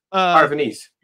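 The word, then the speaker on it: Parravonese: Like, because the Peruvian Wars would have been, or how do you say it? The Paravan? – Which one? Paravanese? – Yes, thank you. – Paravanese.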